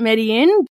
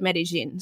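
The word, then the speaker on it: Medellín: And before everyone adds me and says it's Medellin, yes, you can pronounce it Medellin, but the locals to that area actually call it Medellin. So I like to call it what the locals call it.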